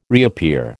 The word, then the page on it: reappear: American English